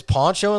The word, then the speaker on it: Pancho: I think it's Poncho and Lefty, but it's spelled Pancho and Lefty.